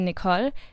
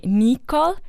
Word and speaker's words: Nicole: I would say Nicole, but others would say Nicole, which I think sounds really weird, like if I had hiccups or something.